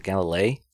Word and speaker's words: Galilei: Galileo Galilei. – Is it Galilei? – Galilei, yeah. – Or is it Galilee? Galilei.